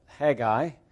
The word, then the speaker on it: Haggai: Some of you pronounce it Haggai, a little extra little syllable in there, or vowel, Haggai.